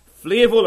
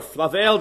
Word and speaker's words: Flavel: It is by the Puritan pastor John Flavel, or Flavel, depends how you want to pronounce his name, F-L-A-V-E-L. And he's written a little work called The Mystery of Providence.